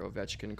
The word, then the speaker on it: Ovechkin: The first one was for an Alexander Ovechkin card. – Yep, and good job on pronouncing that. – I oh wasn't sure if you were going to be able I'm to. Gonna get, get Ovechkin.